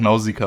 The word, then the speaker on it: Nausikaa: Ja, es wird ja zum Glück im Deutschen auch Nausikaa ausgesprochen.